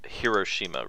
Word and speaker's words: Hiroshima: It's pronounced Hiroshima, Reese.